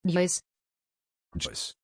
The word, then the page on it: joyce: German